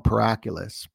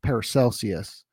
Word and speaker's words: Paracelsus: who I used to call Paraculus, but we get wicked smart. We're going to pronounce the names right Paracelsus.